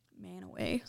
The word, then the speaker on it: Mantua: The cities Manaway. Go on. Manaway. – Spelled like Mantua.